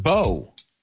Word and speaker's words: proa: It's interesting because it's written like the word bow, un lazo, like rainbow, but it's pronounced bow.